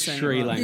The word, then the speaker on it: Sri Lanka: How many water cooler conversations do you think happened after the curry episode of people going, it's Sri Lanka.